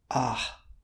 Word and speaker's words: Ah: Ah. It's got the long A, and then you can hear that H at the end.